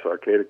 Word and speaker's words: Arcata: Is it Arcata, is how you say that, right? – Yes, Arcata, California.